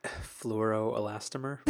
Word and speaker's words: fluoroelastomer: Fluoroelastomer.